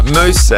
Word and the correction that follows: Mouse: Mouse, are you there? – Hi, good morning. – Sorry, mate, got your name wrong. Not Moose.